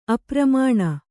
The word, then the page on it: ಅಪ್ರಮಾಣ: apramāṇa